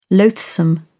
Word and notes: loathsome: British English

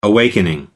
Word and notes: awakening: American English

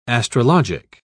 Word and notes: astrologic: British English

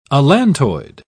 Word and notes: allantoid: British English

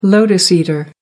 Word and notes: lotus eater: American English

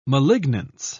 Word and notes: malignance: British English

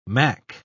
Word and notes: mack: British English

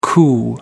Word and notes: coo: American English

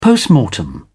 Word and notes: post-mortem: British English

